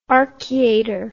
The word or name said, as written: archiater